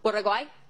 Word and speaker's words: Uruguay: Uruguay.